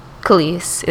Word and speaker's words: Kelis: Kelis is how you pronounce her name.